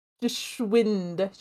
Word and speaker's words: Geschwind: Geschwind.